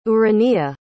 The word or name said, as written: ourania